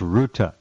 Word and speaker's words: Rutte: Well, how does it's pronounced Ruta? How does Ruta get involved with this? R-U-T-T-E.